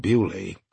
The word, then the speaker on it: Beaulieu: "A friend of mine, Dame Felicity Devonshire, who lives just across the Beaulieu River, spelled the French way but pronounced Beaulieu.